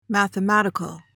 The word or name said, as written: mathematical